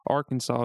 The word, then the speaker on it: Arkansas: State code strictly reinforces that Arkansas is pronounced one way and one way only, three syllables with the accent on the first and last syllable and a silent S at the end.